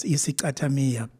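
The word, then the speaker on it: isicathamiya: In terms of the lyrical message, the origins behind that, and I'm going to try to pronounce this correctly, Iskatamiya? – Yes, Iskatamiya. That refers to the dancing of this kind of style of singing.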